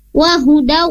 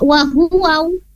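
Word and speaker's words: wahudaw (وَهُدًى): Nah, wahudaw tadi Bu Yuyun membacanya wahuwaw. Nah, di sini ada huda, wahudaw petunjuk ya.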